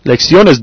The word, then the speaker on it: lecciones: Now, lecciones does end in S, but the stress is on the O. It's on the next to the last syllable.